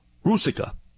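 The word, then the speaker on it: Ruzicka: Well, I don't know if you've seen this story, Chris, but the Arizona Coyotes, they waived and terminated the contract of Adam, I think the last name is pronounced Rusica.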